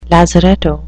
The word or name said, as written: lazaretto